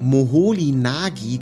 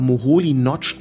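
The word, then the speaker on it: Moholy-Nagy: Also der wird Moholy Nagy geschrieben, aber Moholy Nagy ausgesprochen.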